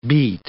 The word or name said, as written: beet